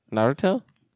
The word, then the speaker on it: Naruto: Naruto? – That's how you say it.